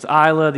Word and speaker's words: Isla: it's Isla, the S is silent, but there's no indication.